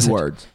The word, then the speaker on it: Squidward: Squidward.